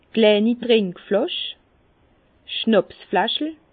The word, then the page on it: kleini Trinkflàsch: Bas-Rhin; Herrlisheim